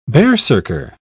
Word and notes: berserker: British English

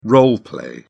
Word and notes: role play: British English

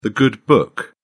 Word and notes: good book: British English

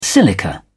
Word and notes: silica: British English

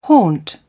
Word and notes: haunt: British English

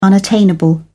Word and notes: unattainable: British English

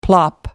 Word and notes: plop: American English

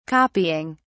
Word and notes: copying: British English

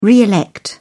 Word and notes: re elect: British English

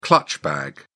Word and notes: clutch bag: British English

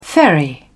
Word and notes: ferry: British English

